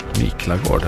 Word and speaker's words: Miklagård: Om jag uttalar det rätt. – Miklagård, ja.